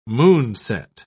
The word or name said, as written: moonset